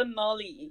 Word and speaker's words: gnarly: So I can say that's so gnarly. – Gnarly. And it's spelt G, like silent G, N-A-R-L-Y.